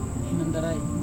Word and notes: hinontarai: Serewen

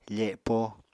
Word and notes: fepo: apatani

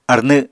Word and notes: arnw: Apatani

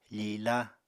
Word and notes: fila: apatani

